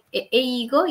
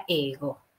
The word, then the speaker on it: Eigo: El idioma inglés es ego. Eigo y se pronuncia ego. ¿Ay? Suena ego, ¿no? Como que tiene mucho ego.